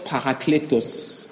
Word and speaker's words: parakletos: Le mot, un autre consolateur que Jésus-Christ utilise ici, c'est l'expression grecque que l'on prononce allos parakletos.